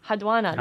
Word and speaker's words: Enheduanna: So there's the first one dates back in Sumer in 2354 BCE, and I think if I say her name correctly, it's Enheduanna. – Haduanna. – There we go.